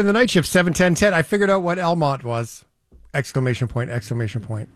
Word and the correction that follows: Almonte: It was El Monte. – Really? – Huh. Yeah. So it's spelled A-L-M-O-N-T-E. Tricky little town. Elmont is actually in Ontario between the Ottawa and Arnprior, Carlton Place.